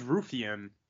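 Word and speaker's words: Raufeon: Is it Ralphian? I always say his name wrong. – I think it's Rufian.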